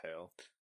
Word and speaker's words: Hale: Like the person that replaced her was Jennifer Hall or Hale. I don't know how to pronounce it. – I believe it's Hale.